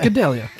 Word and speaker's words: Gadelha: Gadelha.